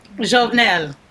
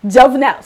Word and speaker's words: Jovenel: I want to say his name right, uh, Juvenal. – Jovenel. – Jovenel. See, you see.